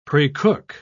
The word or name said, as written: precook